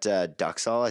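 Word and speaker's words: duxelles: And like that duxal, I think you're right pronouncing it that way.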